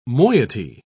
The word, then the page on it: moiety: British English